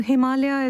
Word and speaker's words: Himalaya: So let's just clear up the pronunciation, because obviously I'm more inclined to go the Himalayas. – Yes. – But in fact, it's singular for you and it's Himalaya.